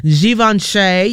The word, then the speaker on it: Givenchy: She was actually a supermodel in some of the best and the biggest fashion houses, such as Givenchy, yeah, that is how you pronounce it, Dolce & Gabbana, Bottega Veneta, and Tom Ford.